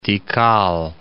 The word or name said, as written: Tikal